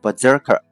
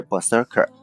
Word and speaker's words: Berserker: 如果你是这个一个职业的游戏爱好者的话啊，那有一个游戏设定呢，你一定是难以忘怀的，就是这个 Berserker。 Berserker 拼成 B E R S E R K E R，B E R S E R K E R。 Berserker 就是名词，叫做狂战士的意思。伊登在这里面提醒大家一下这个词的发音啊，虽然这里面写的是 s，但是呢，它被浊化成 z 的音啊，就是读成 Berserker，不是 Berserker。